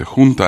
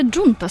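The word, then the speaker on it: junta: Испанская джунта, собрание. Джунта, юнта. Как там? – Собрание. Хунта. – Там нет, так читается. – Да, так читается хунта. Это хота, звук. Хота. Это наследие арабских времен.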